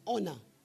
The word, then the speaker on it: honor: That word is not honor. It is honor. It has H, but it is pronounced with an O sound.